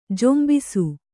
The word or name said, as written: ಝೊಂಬಿಸು